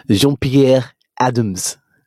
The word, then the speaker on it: Jean-Pierre Adams: Oh, let me say it with the French accent because he's French. Jean-Pierre Adams.